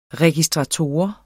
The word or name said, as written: registrator